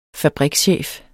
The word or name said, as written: fabrikschef